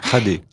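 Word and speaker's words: Xade: Scooper, un bushman du Kalahari central, près de Xad, m'avait raconté une bien curieuse histoire. – Xadé. Ça s'écrit Xad, mais ça c'est Xadé.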